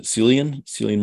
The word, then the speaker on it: Cillian: Is it Cillian Murphy? – Yeah.